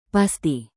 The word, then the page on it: pasti: indonesian